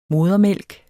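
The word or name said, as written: modermælk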